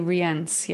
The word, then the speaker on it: Rience: And I know they say it like Ryance or Rience. That's how they say it, right? – I think they say Rience.